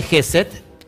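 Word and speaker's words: Jésed: Bueno, lo que miras ahí, que es la esfera de GESET, lo pongo aquí para los que todavía no no saben cómo se pronuncia se llama Geset.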